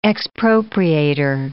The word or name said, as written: expropriator